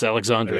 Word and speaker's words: Alexandre: Did I say Alexandra? I meant Alexander. Or which one is it? Alexandre? It's Alexandre.